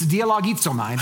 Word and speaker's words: dialogizomai: No, it's dialogizomai. That's how you say it.